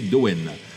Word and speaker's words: Domhain: Nous partons en Irlande du Nord, plus précisément Belfast, pour découvrir le groupe Dowen Alors ça s'écrit Domain, D-O-M-H-A-I-N, mais il paraît qu'on se prononce, alors il y a plusieurs prononciations, mais il y en a une, c'est Dowen Donc Dowen est un projet de quatre musiciens qui jouent du black metal atmosphérique avec des harmonies vocales et du violoncelle.